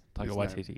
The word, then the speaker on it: Taika Waititi: Taika Waititi is actually how you pronounce Taika Waititi. – Because I feel like everyone in the world was saying Taika Waititi, and then... He did a YouTube video where he said his name, I think it was for Vanity Fair, and then the whole world went, oh, shit. – Mm. – That's how you say it.